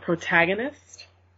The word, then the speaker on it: protagonist: Is it prognist? It's like I know how to say it in my mind, but when I go to say it in like person. – You're talking about protagonist? – Yeah, there you go.